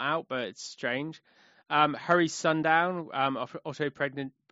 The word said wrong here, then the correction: Otto Preminger: I can never say his name properly. Otto Preminger. Thank you.